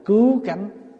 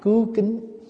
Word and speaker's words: cứu cánh: Có chỗ ghi nhầm, ghi là quy y Phật cảnh, quy y Pháp cảnh, không phải chữ cảnh, chữ cánh. Chữ cánh đây là gì? Là cứu cánh. Người bắt đọc là cứu kính, mình đọc là cứu cánh.